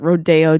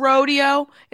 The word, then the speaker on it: Rodeo: Why isn't it Rodeo? – I always loved. – Rich people need to say words differently? – Or because is that the Spanish pronunciation since we took this entire city from the Mexicans? – Oh, good point.